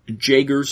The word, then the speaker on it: Jaeger: Because there's other Jaegers around.